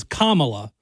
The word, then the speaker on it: Kamala: I think it's Kamala. I think that I think the emphasis is on the first. So it's Kamala.